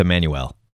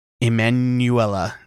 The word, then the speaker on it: Emanuela: I think it's just Emmanuel. – Emanuela. That's exactly how it's spelled.